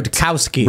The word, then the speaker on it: Rudkowski: Luke Radowski. We've got Rudkowski. – Rudkowski. Is it really? – Yes.